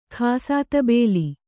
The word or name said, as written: ಖಾಸಾತಬೇಲಿ